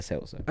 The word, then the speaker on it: seltzer: I always call it a spiltzer. A seltzer? – Oh, so you say it wrong? Yeah, it's a seltzer.